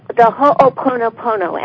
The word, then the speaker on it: Ho'oponopono: I just, as I've been working with this, the Ho'oponopono, am I saying it right? – Oh, you're saying it perfectly.